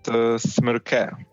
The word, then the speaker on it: Smrke: It's pronounced Smrke. But don't worry, also in my country, people have troubles pronouncing my family name.